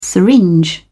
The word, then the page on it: syringe: British English